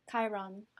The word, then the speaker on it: Chiron: Chiron? – Chiron.